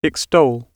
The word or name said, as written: extol